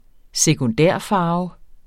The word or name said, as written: sekundærfarve